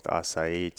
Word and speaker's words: acai: Is that something, I mean, is Asai the one? Is it the chosen one? Am I saying it right? – Acai, yeah.